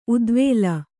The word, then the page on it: ಉದ್ವೇಲ: udvēla